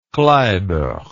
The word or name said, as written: kleiber